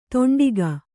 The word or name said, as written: ತೊಂಡಿಗ